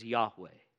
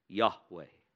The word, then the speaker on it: Yahweh: which we might say is or pronounce as Yahweh. Yahweh.